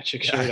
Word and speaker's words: Chișinău: So and the trip to Chișinău was crazy. Yeah, Chișinău.